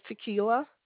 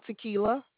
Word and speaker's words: Tequila: My name is Tequila. – How do you spell that? I'm sorry. – Like the drink, Tequila.